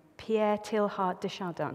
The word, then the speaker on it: Pierre Teilhard de Chardin: I want to read to you a prayer by, and I apologize, I'm going to mispronounce this name, Pierre Teilhard de Chardin. Is that right? I've got a nod.